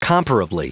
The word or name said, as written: comparably